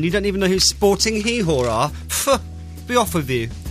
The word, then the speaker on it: Gijón: That's how you say it, Sporting Gijón.